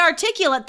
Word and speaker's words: articulate: But the adjective, to be articulate, different pronunciation, right? – Yeah. – To be articulate, that's literally like being fluent, right? – Being able to describe everything that's happening.